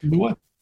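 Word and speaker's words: Emunah: This goes along with the Emua. Did I say that right? – Yeah, that's perfect. – Emua.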